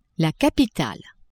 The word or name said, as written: capitale